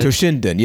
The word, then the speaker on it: Toshinden: Hopefully Toshinden. – I said that right. – Toshinden, yeah, you tried.